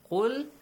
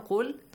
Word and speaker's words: قُلْ: قُلْ. Everyone, قُلْ.